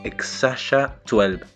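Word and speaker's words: X Æ A-12: Eh, el nombre es, se pronuncia Exasha 12.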